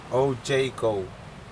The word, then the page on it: oujeiko: Aneityum